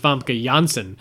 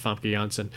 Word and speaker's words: Famke Janssen: But Yeah, the Dutch pronounce the J as a Y sound. Mm-hmm. So most people pronounce it wrong, but it is pronounced Famke Janssen.